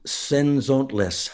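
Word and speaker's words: Cenzontles: By the way, you pronounce it Senzontlis. – Ah, okay, Senzontlis. – And it means the mockingbirds.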